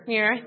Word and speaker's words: Muir: Muir, thank you.